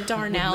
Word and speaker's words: Darnell: No Darnell, okay? That's what I thought. – Darnell. – Daryl. – It's Darnell. Come on, dude. – Dar. How you spell it? – D-A-R-N-E-L-L. – That's what I wrote. – That's not... Then you pronounced it wrong. – Darnell. Darnell. – Daryl from The Walking Dead. – That was an office. N, not an R.